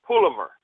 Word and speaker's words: palaver: Pullover. And pullover is spelled P-A-L-A-V-E-R. Pullover. I thought it was palaver, but the pronunciation looks like pullover.